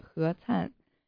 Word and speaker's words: Canhe: 你决定不是第一个叫错我的姓的美国人，确实挺奇怪的，有些中文名字的发音和英文发音截然不同，比如我一个朋友的名字拼写是 C-A-N-H-E, 你觉得应该怎么念他的名字？ – Can he? – 哈哈，我就知道你会念错，正确的发音是何灿。